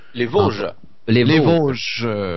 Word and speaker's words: Vosges: Comment ça se prononce? En Vosges? – Les Vosges. – V-O-S-G-E-S.